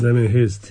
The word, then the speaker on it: Damien Hirst: creo que se pronuncia así, Damien Hirst. – Damien Hirst, sí, sí.